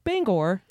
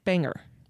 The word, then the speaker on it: Bangor: And yes, it is pronounced Bangor and not Banger.